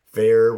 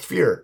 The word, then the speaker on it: Feyr: Fair, which... pronounced fear. – Yeah. – Spelled F-E-Y-R, but pronounced fear.